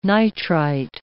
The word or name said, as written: nitrite